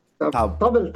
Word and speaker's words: tabl: Não, tem um, um instrumento chamado T-A-B-L. Tabel, tabel.